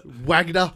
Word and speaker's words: Wagner: Wagner. – Yeah. – God damn it. That's right. That's right. Yeah. – It's like Arsene Wenger.